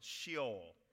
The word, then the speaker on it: Sheol: The first word is the word, it looks like Sheol, and that's almost how you pronounce it, Sheol in Hebrew.